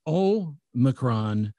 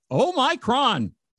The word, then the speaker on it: Omicron: Uh, so the emphasis is on the first syllable. So it's Omicron, not Omicron.